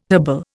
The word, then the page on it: herbal: Indian